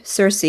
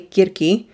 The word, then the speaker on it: Circe: And after that, she released a second book called Okay, if you pronounce it the English way, Circe. If you pronounce it the Greek way, Kirki.